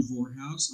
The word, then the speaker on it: Vorhaus: Okay, the only thing you really need to know is my name, John Vorhaus. I'll spell it for you, J-O-H-N, V as in victory, O-R-H-A-U-S.